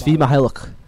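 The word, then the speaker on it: Svi Mykhailiuk: and I always mispronounce this guy's name, Sevi I'm not even going to Sevi Mihailuk. – Thank you.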